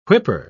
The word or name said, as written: whipper